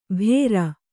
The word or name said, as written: ಬೇರ್